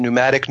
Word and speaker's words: pneumatic: Whenever you see the PNU prefix, like pneumatic, where the P is silent, pneumatic, pneumonia, right?